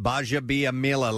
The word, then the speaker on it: Gbaja-Biamila: G-B-A-J-A-B-I-A-M-I-L-A. – And that's pronounced Baja Biamila.